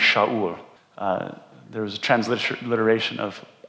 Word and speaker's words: Shaul: We know that Paul's Jewish name was Saul, pronounced in the Hebrew as Shaul.